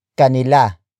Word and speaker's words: kanila: Word for word, it's ilan sa kanila ang sasama sa akin.